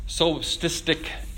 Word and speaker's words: solipsistic: Number three, this solipsistic introjection.